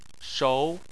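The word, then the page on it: shou: tone 2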